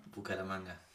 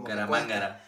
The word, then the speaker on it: Bucaramanga: Que yo ya lo, ya lo pronuncio muy bien, pero las primeras veces también como Bucaramangara. – Porque no, sí, uno dice Bucaramangara y otro suena como un trabalengua.